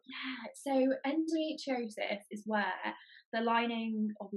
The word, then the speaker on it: endometriosis: Can you pronounce it for me? Because I just didn't know how to say it. – So it's endometriosis.